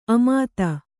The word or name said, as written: ಆಮೋದ